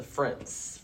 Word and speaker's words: Frint: If you haven't met the Frints. – How do you spell that? – F-R-I-N-T, like Flint, but with an R. – F-R-I-N-T, okay.